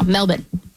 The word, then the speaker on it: Melbourne: Apparently they don't pronounce the R. – Okay. So no R's. – No, Melbourne.